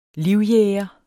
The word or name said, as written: livjæger